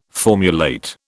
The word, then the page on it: formulate: British English